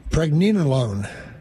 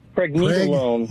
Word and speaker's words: Pregnenolone: I had never heard about, how is it pronounced again? Pregnenolone? – Pregnenolone. – Pregnenolone. – Pregnenolone. N-E-N-O-L-O-N-E. – There you go.